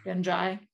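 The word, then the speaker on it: Ghenjei: Okay, so chapter 28 is the one that you were mentioning, the to the tower of Genji. Did I say that right? – Genji. That's how the narrators pronounce it.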